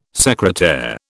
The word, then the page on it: secretaire: British English